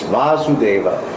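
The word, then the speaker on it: Vāsudeva: So here he wants to say that this, that's why he's saying this Vasudeva with the long A, here denotes the son of Vasudeva, therefore it means Sri Krishna.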